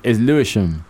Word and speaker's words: Lewisham: It's Lewisham.